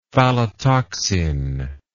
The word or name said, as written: phallotoxin